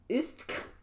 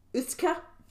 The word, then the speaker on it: Ustka: a German steamship was discovered north of the Polish seaside town of Ustk. Ustka. I like that you're you're you're getting so mad at everyone for mispronouncing German words, and then you're getting Look.